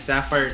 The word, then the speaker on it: Safyre: It's like Sapphire, but it's spelled S-A-F-Y-R-E. Her name is Sapphire Terry.